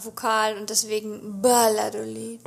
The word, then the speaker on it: Valladolid: Wir sind in Valladolid, diese Stadt schreibt man mit V, aber sie wird mit B ausgesprochen. Why the heck?